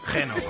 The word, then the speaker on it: Geno: Antes tuvieron otro éxito, en realidad, que se llamaba Geno, o Gino, no sé cómo se pronuncia. Geno, es como lo de los restaurantes, pero sin ese. – Geno.